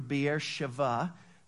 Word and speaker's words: Beersheba: But remember, they went up to, you might read it in your Bible, Beersheba. It's pronounced in the Hebrew, Beersheva.